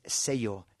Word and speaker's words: sello: So you can just ask for the word for stamp in Spanish is sello, sello, S-E-L-L-O, but that double L is pronounced like a Y in English.